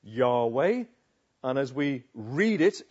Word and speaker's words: Yahweh: In the Hebrew, no one's quite sure how it's pronounced. But the word is probably something sounding like Yahweh.